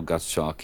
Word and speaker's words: Gottschalk: Help me with the pronunciation of the composer's name. Gottschalk? – That's right. Louis Moreau Gottschalk.